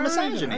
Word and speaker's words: misogyny: How do you say it? You're saying it right. Misogyny. – It sounds wrong.